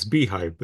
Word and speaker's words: beehiv: It's beehive, but spelled very incorrectly.